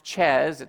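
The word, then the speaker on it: Thiès: And then we started out in a major city called Dakar, and then we traveled to a town that's pronounced Chez. It's spelled with a TH, but it's locally they call it Chez.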